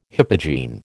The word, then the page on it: hypogene: American English